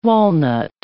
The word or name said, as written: walnut